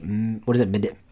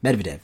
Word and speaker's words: Medvedev: On December 7th, 2012, then Russian Prime Minister Dmitry, Medvedev. – Medvedev. – There you go. Medvedev.